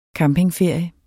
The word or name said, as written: campingferie